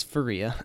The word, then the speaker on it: Faria: I think I've been pronouncing his name wrong the last couple of podcasts. It is Faria.